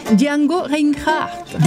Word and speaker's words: Django Reinhardt: Además, rinde homenaje a mi más grande ídolo, Django Reinhardt. – Ay, pero qué mal pronuncias, de verdad. – Pues es que escucha. Django Reinhardt.